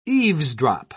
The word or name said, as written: eavesdrop